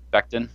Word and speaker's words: Becton: Mekhi Becton, tackle Louisville. I think that's how you say his name. I don't even know how you say his name. – Becton, yeah. – Becton.